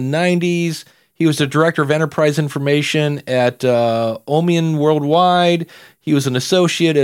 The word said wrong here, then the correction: Omnium: And you've mispronounced those two businesses. – Ah, there we go. – You didn't run it by me. It was Omnium and Candence.